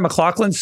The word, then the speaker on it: McLaughlin: When I saw the running backs available on waivers and I saw Jaleel McLaughlin, my mind immediately jumped to Sarah McLaughlin. Is that how you say her name? Sarah McLaughlin's song? – Yes.